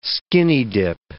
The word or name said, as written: skinny-dip